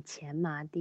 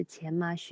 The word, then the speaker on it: 荨麻: 从这个 tunnel 里面出来以后呢，这两只小猫咪呢来到了一片前麻地。当然，这是一个多音字，前麻、荨麻都可以哈。